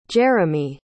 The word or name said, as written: jeromy